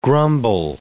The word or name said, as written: grumble